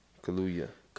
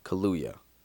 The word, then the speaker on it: Kaluuya: Kaluuya. – Kaluuya. Daniel Kaluuya. Yeah, held him back, and then Daniel Kaluuya jumped at him after.